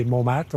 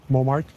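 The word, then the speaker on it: Montmartre: would say that the people that don't know nothing about the community would spell it differently than when we say MoMat or MoMart.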